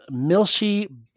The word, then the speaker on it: Milshi: All right, so our first email comes from someone by the name of Milshi Bubs. And in case I couldn't pronounce the name Milshi, they even put it in one of those parentheses that says Mil-Shi.